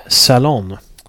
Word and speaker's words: Salon: utan det är en intervju i nättidningen Salon, om man uttalar det på engelska. S-A-L-O-N, nätpublikation.